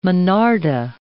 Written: monarda

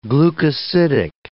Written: glucosidic

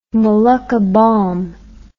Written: Molucca balm

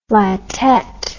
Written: flatette

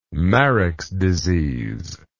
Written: mareks disease

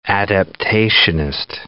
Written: adaptationist

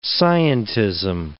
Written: scientism

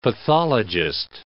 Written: pathologist

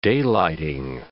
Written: daylighting

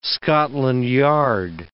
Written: Scotland Yard

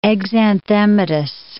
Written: exanthematous

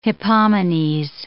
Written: Hippomenes

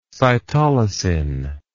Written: cytolysin

